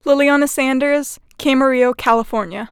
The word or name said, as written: Lilliana Sanders